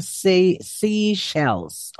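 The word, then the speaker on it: Seychelles: Ang pronunciation pala po nito, seashells. Oh, seashells.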